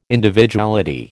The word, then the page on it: Individuality: American English